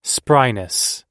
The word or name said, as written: spryness